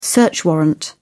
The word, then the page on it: search warrant: British English